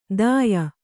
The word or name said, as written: ದಾಯ